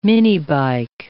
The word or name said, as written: minibike